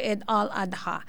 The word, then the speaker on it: Eid al-Adha: Ed al-adha. Opo. Ayun ang pag-pronounce no, no? Baka magkamali tayo. Eid al-Adha. Oo.